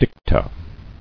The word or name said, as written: Dicta